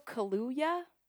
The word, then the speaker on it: Kaluuya: And I literally was like, I know how to say this name. It's Daniel Kaluuya.